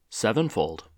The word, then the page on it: sevenfold: American English